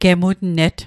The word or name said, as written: gemutnet